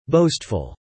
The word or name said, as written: boastful